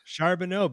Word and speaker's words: Charbonnet: Charbonneau, baby. I went to LSU, Cajun French. It calls it Charbonneau.